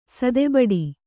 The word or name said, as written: ಸದೆಬಡಿ